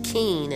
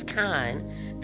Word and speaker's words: kine: So when Pharaoh tells him about these kine, and he tells them, and for those of you who don't know, and I have been pronouncing it wrong for a minute, I was saying keen and it's kine.